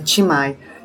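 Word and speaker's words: Thi Mai: É Ti, eu, estou dizendo a pronúncia em português, né? Porque é um nome vietnamita. É Ti com T, é T-H-I e né? M-A-I, né? Timai.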